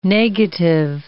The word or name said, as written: negative